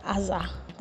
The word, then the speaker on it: Azar: So using the word Ezer as a verb, it is pronounced Azar. That is A-Z-A-R.